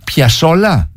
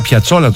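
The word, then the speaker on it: Piazzolla: Και Άστορ Πιασόλα. – Σωστότατα. – Μισό λεπτό. Το άκουσα να το λέει. Εγώ τον έλεγα Πιατσόλα τόσο καιρό.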